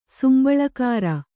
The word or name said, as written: ಸಂಬಳಗಾರ